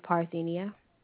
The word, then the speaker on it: Parthenia: My name is Parthenia.